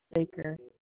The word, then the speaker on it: Baker: So it's M-E-L-A-N-Y. Okay. Okay, and how do I spell the last name? – Baker. B-A-K-E-R. – A-C-K-E-R? – No, Baker. B-A-K-E-R.